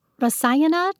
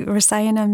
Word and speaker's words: Rasayana: Rasayana, did I say it right? It means kind of rejuvenating, right? – Exactly. Rasayana means rejuvenating, nourishing.